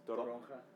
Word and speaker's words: toronja: Is toronja.